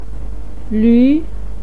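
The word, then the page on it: luu: tone 2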